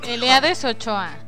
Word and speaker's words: Eliades Ochoa: Si no han tenido la oportunidad de escucharlo, así como lo acaban de escuchar, así se pronuncia. – Elías Ochoa.